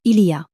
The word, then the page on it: il y a: French